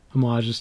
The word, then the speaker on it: homages: But there have been a couple other homages to that, but that's homages. I like to pronounce the H. Mm-hmm. Sue me. Homages.